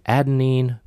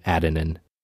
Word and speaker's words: adenine: Some people pronounce his name adenine, while other people called him adenine.